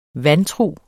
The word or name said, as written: vandtrug